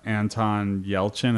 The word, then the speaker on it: Yelchin: And then the last news I had out of Comic Con I want to talk about was uh, Anton Yelchin.